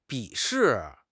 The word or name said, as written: 鄙视